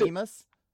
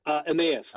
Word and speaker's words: Emmaus: Is it Emus? – Emmaus. Emmaus. The Road to Emmaus.